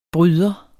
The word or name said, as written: bryder